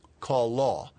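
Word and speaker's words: chalah: Kalah. It's C-H-A-L-A-H. Kalah. Hebrews usually emphasize the last syllable.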